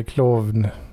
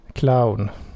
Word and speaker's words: Klovn: Kla, eh, klovn. Klon. Clown. Clown. Ja, inte jättebra på danska kanske. Klon. Clown. Fast K med K och enkel V.